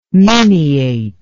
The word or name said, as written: miniate